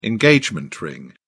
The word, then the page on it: engagement ring: British English